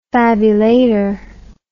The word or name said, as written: fabulator